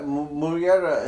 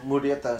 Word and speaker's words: Murrieta: No, Murrieta, Murrieta is how you say it in like English, but Murrieta is how you really pronounce it.